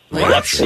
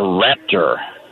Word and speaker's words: Rapture: They all say Rapture, like it's a holy thing. – Rapture. – Oh, the Rapture. – Rapture. They build the Rapture, not a Raptor.